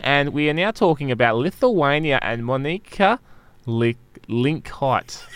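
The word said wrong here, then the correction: Monika Linkytė: Would you like me to tell you how it's said? Yes, please, okay. Please. – It's Monika Linkate. Yeah. – Yeah. Okay, no, I didn't get that. I was way off.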